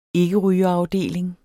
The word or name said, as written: ikkerygerafdeling